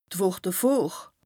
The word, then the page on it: d' Wùch devor: Bas-Rhin; Strasbourg